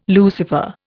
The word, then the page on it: Lucifer: Indian